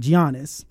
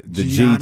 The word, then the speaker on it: Giannis: There are still basketball people who add the Giannis. – G to it.